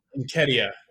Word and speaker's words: Nketiah: Marquinhos, Nketa, right? Or Nketiah, right? How do you say his name? I keep saying it wrong. – It's Nketiah, isn't it? – No, I don't think that's it. Aaron Ramsdale. – Nketiah. – Yeah, that's better.